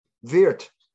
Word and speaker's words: Wirths: W-I-R-T-H-S. – Okay. Oh, Edward, right? – Yes. – So I pronounced it. Okay, yeah, that's German. You're doing the German.